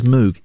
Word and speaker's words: Moog: His name is Robert M-O-O-G. Last name is M-O-O-G. Everyone says moog. It's not moog. It's pronounced moog.